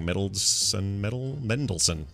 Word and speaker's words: Mendelsohn: Mendelsohn.